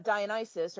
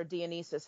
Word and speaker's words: Dionysus: to Dionysus or Dionysus, however you want to pronounce it, potato, potato.